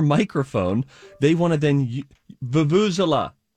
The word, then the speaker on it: vuvuzela: So you can practice it and then get close to the thing that we're all striving for today, which is vuvuzela.